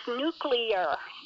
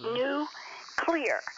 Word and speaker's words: nuclear: The word is nuclear, like the last letters are clear. The word clear. Nuclear. It is new clear.